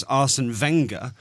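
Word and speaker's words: Arsène Wenger: And then when I was told that it is Arsene Wenger, first thing that came into my head was Wenger Boys, obviously.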